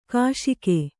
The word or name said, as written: ಘೋಷಕ